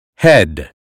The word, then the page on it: head: American English